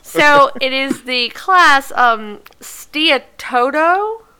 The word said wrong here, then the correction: Steatoda: Stoda? Steatoda? – Just be confident. Be confident in whatever you say, and no one can say you're wrong. – I'm going to spell it. It's Steatoda.